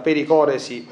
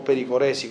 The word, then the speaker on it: pericoresi: Poi qui c'è un parolone che è la pericoresi o pericoresi come preferite a seconda se fate la pronuncia latina o greca,